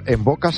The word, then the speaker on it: Mvoca: Envoca se escribe M-V-O-C-A.